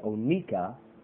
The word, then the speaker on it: onycha: Now, and onyca, as it's pronounced, O-N-Y-C-H-A, onyca, is the shell of a species of mussel found in the lakes of India, which when burned emits a musky odor.